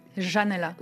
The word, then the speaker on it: Janela: Ils ne disent pas le « je ». Janela.